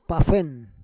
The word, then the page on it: pafen: Roon